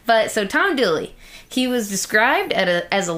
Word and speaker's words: Dula: Tom Dooley. The real Tom Dooley is spelled D-U-L-A. Turns out it's actually pronounced Dooley.